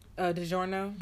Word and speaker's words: DiGiorno: DiGiorno.